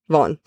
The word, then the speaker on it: Vaughan: V-A-U-G-H-A-N. – Vaughn? – Vaughn.